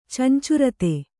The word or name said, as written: ಚಂಚುರತೆ